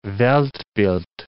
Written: Weltbild

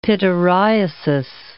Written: pityriasis

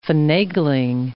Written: finagling

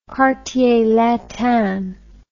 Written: quartier latin

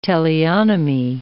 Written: teleonomy

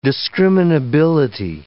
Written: discriminability

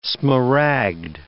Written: smaragd

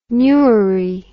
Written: newry

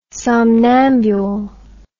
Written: somnambul

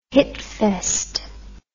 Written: hitfest

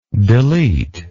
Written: deletable

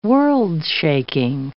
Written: world-shaking

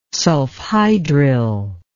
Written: sulfhydryl

